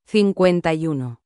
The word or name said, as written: 51